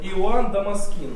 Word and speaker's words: Дамаскин: Иоанн, и такое имя, Иоанн Дамаскин. Иоанн Дамаскин. Или Дамаскин. Дамаскин, Дамаскин. Ну, мне кажется, православно говорят Дамаскин почему-то. Ну да, ударение на «и», наконец. Иоанн Дамаскин.